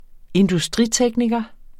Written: industritekniker

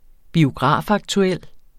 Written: biografaktuel

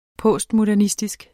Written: postmodernistisk

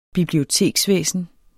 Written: biblioteksvæsen

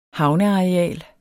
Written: havneareal

